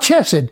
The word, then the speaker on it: chesed: It's not k, which would be the thought if somebody saw it printed in some references, printed C-H-E-S-E-D. But that could also be chesed. Some translations or reference manuals spell it K-H-E-S-E-D, which would be more like the chesed. But it's not chesed, and it's not chesed. It is chesed.